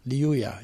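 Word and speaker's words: Lliuya: Liuya, ja, dubbel L.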